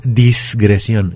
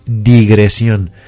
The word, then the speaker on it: digresión: La palabra correcta es digresión. Ajá. Sin la S. Es decir, no es disgresión, es digresión. Que se escribe con S y con acento en la O, dicho sea de paso. Esta palabra es un error común. Por alguna razón la confundimos y creemos que se tiene que decir dis cuando es digre.